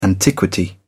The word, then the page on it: antiquity: British English